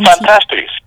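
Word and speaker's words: Phantastes: Say it again? – Was it Fantastes? – Oh, Fantastes. – Fantastes. Okay, that's how you pronounce it. – Yeah, Fantastes.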